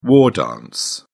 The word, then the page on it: war dance: British English